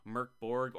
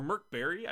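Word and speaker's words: Mörk Borg: The author here of this piece, Chase Carter, compares it to Merc Borg, or Merc Berry, I believe it actually is supposed to be pronounced. I found that out recently. Right? But one of the creators said, just say Merc Borg, it's fine.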